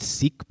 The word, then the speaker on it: Sikh: This is a man who is of the, it's often pronounced Sikh, but I keep being corrected that it is Sikh.